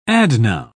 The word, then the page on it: adnoun: British English